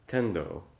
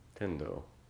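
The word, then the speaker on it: Tendō: So where I was going with that is the characters Tiantong, Chinese, are pronounced by the Japanese Tendo. Tendo.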